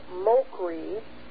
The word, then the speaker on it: Mochrie: And the first one is Rob Mokree. And his last name is spelled M-O-C-H-R-I-E.